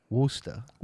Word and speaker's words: Worcester: Worcester. Say it again. – Worcester. – Worcester? – Yeah, just like Worcester, Mass.